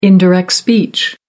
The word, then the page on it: indirect speech: American English